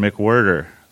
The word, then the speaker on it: McWhirter: So I am with Christian McWhirter.